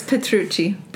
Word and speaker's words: Petrucci: It's Petrucci. – Petrucci. – Yeah. – Okay. Is that like Italian? – It's or? – Very much Italian.